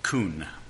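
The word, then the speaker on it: kun (כון): In the Hebrew word kun, it's spelt K-U-N, pronounced K-O-O-N.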